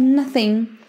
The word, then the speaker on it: Nothing: I have nothing. Nothing. Conhece a música? Nothing. Beleza. Mais uma vez, não pronunciamos o G no final.